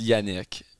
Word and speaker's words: Yannick: It's Yannick. – Okay. – Yannick Plante.